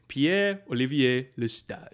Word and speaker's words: Pierre-Olivier Lestage: Now, so if I was going to do like full on French pronunciation, Pierre-Olivier Lestage.